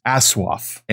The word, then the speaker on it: ASOIAF: Now, this was a thing from Maester Monthly, and we decided to pronounce it as asswaff.